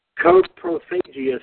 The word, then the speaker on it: coprophagia: And it came on, and I'm going to do my best to pronounce this, coprophagia scene. That's how I pronounce it.